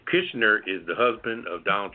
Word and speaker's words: Kushner: Kushner.